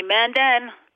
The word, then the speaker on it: Mandan: So can you say them correctly so everyone knows? – Yes, it is the Mandan Hidatsa and Arikara Nation.